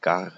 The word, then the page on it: done: /dʌn/